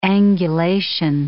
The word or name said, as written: angulation